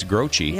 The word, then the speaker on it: Groce: It looks like Croce, but it's Groce.